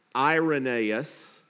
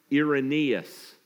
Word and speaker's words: Irenaeus: And his name gets pronounced different ways. His name, sometimes you will hear people say Irenaeus.